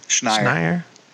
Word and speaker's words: Schneier: is it Schneier? – Schneier.